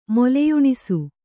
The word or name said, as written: ಮೊಲೆಯುಣಿಸು